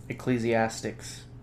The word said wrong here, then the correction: Ecclesiastes: Or it'd be Ecclesiastes. That's a book in the Bible.